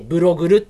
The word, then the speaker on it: BLOGLUE: ブログル、はい、ね、 BLOGLUE でブログルって読むんですけど、ここはまあ今流行りのね、ブログパーツとか、まあ、ホームページにも貼れるこうガジェットと呼ばれるものとか、うん、そういったものを紹介するサイトなんですよ。